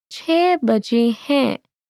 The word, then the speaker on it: chhe baje hai: It's 6 o'clock in Urdu is chhe baje hai. Chhe baje hai. Let's break it down by syllable. Chhe baje hai. Let's hear it one more time.